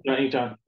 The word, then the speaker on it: Incheon: Incheon? – Incheon, yeah.